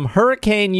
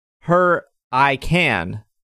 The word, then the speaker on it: Hurricane: The pronunciation the pr- pronunciation of my hometown, Hurricane is pronounced her I can, not and not hurricane like it's spelled.